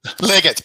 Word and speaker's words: Leggett: Is it Leggett or Leggett? How do I say that? – Leggett, but you're close.